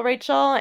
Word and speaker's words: Rachel: And also Facebook, same thing, Adorkable Rachel.